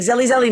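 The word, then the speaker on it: Zelle: I was like, honey, it's not Zelly, just Zell.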